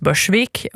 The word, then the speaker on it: Bölsvik: På Åland har vi också, vet du, vi har Bölsvik som uttalas Börsvik och Rågetsböle som uttalas Röjsböle.